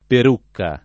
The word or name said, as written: Perucca